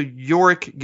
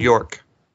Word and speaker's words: Yorick: They miss a syllable. – Yorick. It's Like, literally, you could split it up. Yo, Rick. Yo, Rick. – Yeah. – Literally. I'll put the two together. Yorick. Yurk. Yurk. It's like, oh, my God. There's an O, not a U. Or an E.